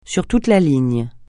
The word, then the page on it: sur toute la ligne: French